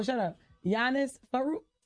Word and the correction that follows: Varoufakis: Yeah, exactly. – Varoufakis. – Now, now you on stage. Right. Now they mad because you said their name wrong.